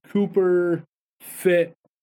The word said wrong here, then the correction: Copper Fit: Is that Cooper Fit or Copper Fit? – Copper Fit. Sorry about that. Mispronounced the word there.